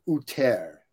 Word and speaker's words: Utter: I think you're one of three people in the United States that pronounced my name. It's actually Uter.